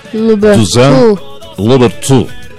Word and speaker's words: Toussaint Louverture: Haití logró su independencia de Francia en el año 1804, después de una gran rebelión de esclavos liderada por figuras como Toussaint Louverture. Ese francés me sonó. – Toussaint Louverture. – ¿Sí? – Louverture. – Como que se cala en la garganta. Louverture.